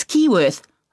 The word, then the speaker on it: Keyworth: It's Keyworth. I'll spell that. K-E-Y-W-O-R-T-H.